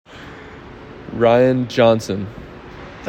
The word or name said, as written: Ryan Johnson